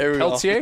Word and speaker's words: Pelletier: Raphael Pelletier. I'm saying that right. Pelletier? – Go. Yeah. – All right, I got the proper Francais pronunciation in there.